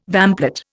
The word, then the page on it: vamplate: Indian